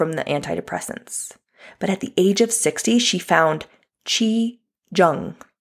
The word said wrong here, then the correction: Qigong: Qigong. I don't want to say that wrong. It's Qigong, G-O-N-G.